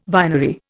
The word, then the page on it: vinery: Indian